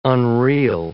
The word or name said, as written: unreal